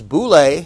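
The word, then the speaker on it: boule (βουλή): The first two are a noun and a verb, thelo and thelema, G2307 and 2309. And also G1012 and 1014, which is boule and boulamai if you want the Greek words. That's how they're pronounced.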